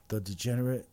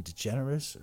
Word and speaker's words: DeGeneres: I always thought it was Ellen the Degenerate. Apparently it's just Ellen DeGeneres or something.